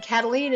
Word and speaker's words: Kataline: Karen is spelled the normal way, no goofy Y's or Q's, K-A-R-E-N and Kataline is spelled K-A-T-A-L-I-N-E.